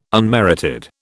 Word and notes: unmerited: British English

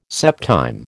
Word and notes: septime: American English

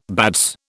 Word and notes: bats: British English

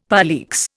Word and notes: poleaxe: Indian

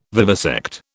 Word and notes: vivisect: British English

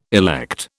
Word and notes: elect: British English

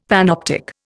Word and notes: panoptic: Indian

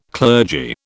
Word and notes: clergy: British English

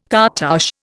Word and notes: cartouche: Indian

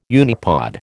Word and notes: unipod: American English

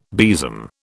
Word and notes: besom: British English

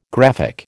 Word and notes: graphic: American English

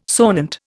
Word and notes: sonant: Indian

